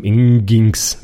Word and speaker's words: Nginx: also NGINX, ja, geschrieben, Nginx, ja, der wird Nginx ausgesprochen,